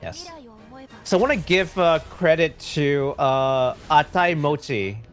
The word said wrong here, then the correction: Aitai Kimochi: Oh, sorry. Aitai Mochi. Aitai Kimochi. I can't always mispronounce her name. Aitai Kimochi for translating this one for us.